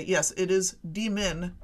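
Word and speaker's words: D.Min.: But notice when you say demon, the accent is on the D, and when you say demon, the accent is on the min.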